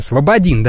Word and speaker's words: Слободин: И СКР сейчас интересуется местом пребывания Михаила Слободина. Это гендиректор Вымпелкома. С ударением я правильно разобрался? Слободин, да.